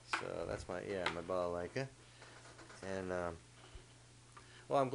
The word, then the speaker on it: balalaika: balalaika. Did I pronounce that correctly? – You did.